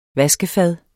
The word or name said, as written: VASKEFAD